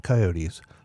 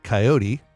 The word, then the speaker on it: coyote: Now, before we go any further, this animal's name can be pronounced either coyote, the three-syllable version, or it can be pronounced the two-syllable version, coyote. Some people even like to shorten it to be yotes, as in, I was out all day looking for some yotes.